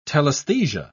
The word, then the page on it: telaesthesia: British English